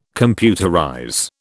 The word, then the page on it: computerize: British English